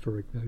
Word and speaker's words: Ferrigno: Which also starred Lou Ferrigno, I keep trying to say it like the Italians would, but I think he pronounced it Ferrigno, didn't he? – Uh-huh.